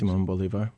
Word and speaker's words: Simón Bolívar: When you think back on the history of Latin America, Simón Bolívar, how would you say that? – Simón Bolívar.